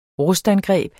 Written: rustangreb

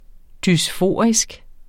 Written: dysforisk

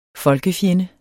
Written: folkefjende

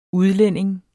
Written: udlænding

